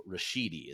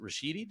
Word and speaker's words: Rashidi: So Rashidi, is it Rashidi? Did I say that right? – You nailed it. – Rashidi.